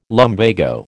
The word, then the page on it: lumbago: American English